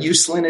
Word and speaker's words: Uslan: But Uslan is it.